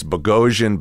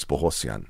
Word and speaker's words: Bogosian: You know, it's like it's Bogosian, Bogosian. What is Bohosian? Well, the correct pronunciation is Bohosian.